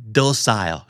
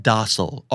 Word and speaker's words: docile: docile หรือว่า docile ออกเสียงได้สองแบบ docile or docile